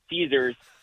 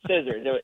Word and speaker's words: scissors: They're both calling it Caesars, and everyone's like, no, it's not like Caesar salad. It's scissors.